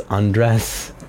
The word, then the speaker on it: Andres: When I say the word right before, when I think like about my name, like Andres, some people are like, your name's Andres? Like, how do you pronounce it? And I'm like, no, it's Andres.